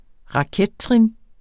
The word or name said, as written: rakettrin